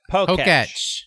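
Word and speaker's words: Pokétch: How do you say it? – Poketch. – Poketch. That's a Poketch. – Like Etch a Sketch.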